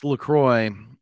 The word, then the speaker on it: LaCroix: Is it LaCroix? That. Is that how you pronounce that? – It's LaCroix, but this is the best.